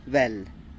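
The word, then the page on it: bel: Teotitlán del Valle Zapotec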